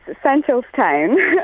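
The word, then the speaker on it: Seneschalstown: Well, you come from Senescalston. Will you give us, please, on Late Lunch LMFM today, the definitive pronunciation? – I do, and it's Centralstown.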